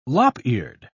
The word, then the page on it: lop-eared: British English